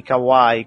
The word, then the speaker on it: Kawhi: Kawhi,